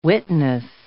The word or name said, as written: witness